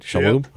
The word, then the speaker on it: Shalhoub: as well as Tony Shalhoub. Am I saying that right? Shalhoub? – Yeah.